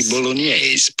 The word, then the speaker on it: bolognese: Of course, in English or American, you normally say it bolognese. Bolognese, but the typical Italian pronunciation is quite different. In fact, in Italian, they say bolognese.